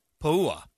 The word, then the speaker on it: Puha: Puha. Yeah. – Puha. – Because there's an apostrophe, so you've got to break that up. – Po-u-ha. – Po-u-ha. – Po-u-ha. – That's Polynesian pronunciation.